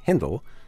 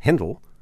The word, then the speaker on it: Händel: Handel was born in Germany, where his family name was pronounced Händel, and where he was baptized Georg Friedrich. If you prefer to stick with that original pronunciation, Händel, especially if you happen to be in Germany, you're definitely on safe grounds.